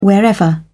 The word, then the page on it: wherever: British English